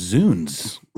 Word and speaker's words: Zounds: So it should be pronounced Zounds. – It should be. – Z-O-U-N-D-S, yeah.